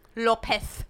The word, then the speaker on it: López: López. With the TH.